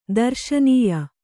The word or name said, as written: ದರ್ಶನೀಯ